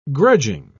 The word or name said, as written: grudging